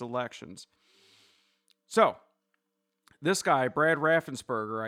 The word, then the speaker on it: Raffensperger: I wanted to say that correctly because it could be Raffenberger, but it's Raffensperger with a P, Perger, like burger with a P.